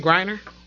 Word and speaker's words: Griner: Brittany Gardner, our good folks at the Act Accordingly podcast. You know, they love. – Brittany Griner? – I thought I said that right.